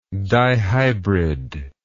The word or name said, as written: dihybridism